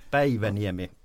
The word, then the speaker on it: Päiväniemi: Det är bra och ditt uttal på mitt efternamn var väl eh, två plus kanske. – Två plus. Men, men, men, det funkade. – Eh, Päiväniemi, mjukare P.